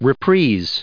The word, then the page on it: reprise: American English